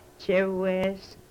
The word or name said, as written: chewes